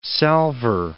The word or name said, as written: salvor